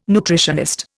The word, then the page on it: nutritionist: Indian